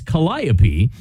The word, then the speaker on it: Calliope: Which could either be, when I read that, I think Calliope. But Calliope. – No, it's Calliope.